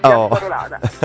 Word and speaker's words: Piazzarolada: Dunque il prossimo fine settimana 14, 15 e 16 di luglio sarà tempo di Piazzarolada, giusto? L'ho detto bene? – Giusto, sì, Piazzarolada.